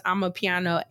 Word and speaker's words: amapiano: Pronounced I'm a piano, A-M-A-P-I-A-N-O.